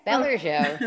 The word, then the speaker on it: Bellicho: And I'm going to talk today to Claire Bellicho. Claire, did I say it correctly? – Yes, Bellicho. Yes, that's right.